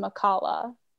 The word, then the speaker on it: Makala: It should be pronounced Makala, but actually I work at Makaha. So instead of the L, it's an H.